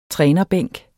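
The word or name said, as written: trænerbænk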